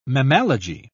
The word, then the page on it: mammalogy: British English